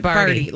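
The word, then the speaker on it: Bardi: Rhymes with Cardi, but it's Barty.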